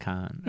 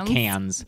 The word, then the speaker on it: Cannes: Got the Golden Laurel, like, or the Golden Palme d'Or at like Cannes. Anyway, I always forget that it's pronounced Cannes. – It's not Cannes.